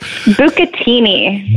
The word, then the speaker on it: bucatini: Bucatini.